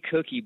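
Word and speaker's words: ACoucke: Allie's handle is A Cookie, but it's A-C-O-U-C-K-E. Yeah. But it's pronounced Cookie for all those who might be wondering.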